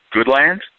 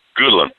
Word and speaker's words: Goodland: We were in Goodland, Kansas, which apparently is not pronounced Goodland. It's pronounced Goodland.